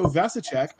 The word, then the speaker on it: Vasicek: So we've got as a special co-host, Mr. Joe Vasicek. I hope I pronounced that right. – That's correct. Vasicek like cash a check.